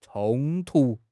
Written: 冲突

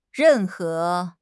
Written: ren4 he2

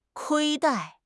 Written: 亏待

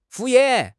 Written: 敷衍

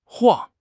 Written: 晃

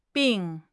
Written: bing4

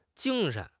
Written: jing1 shen2

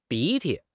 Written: 鼻涕

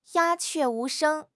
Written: 鸦雀无声

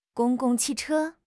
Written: gong1 gong4 qi4 che1

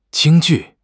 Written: Jing1 ju4

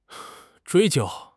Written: 追究

